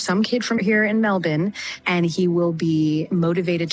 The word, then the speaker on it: Melbourne: I'm impressed at an American pronouncing Melbourne correctly. She's saying Melbourne, 12 away from 7.